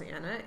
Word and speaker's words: Anna: So my name is spelled as Anna, A-N-N-A.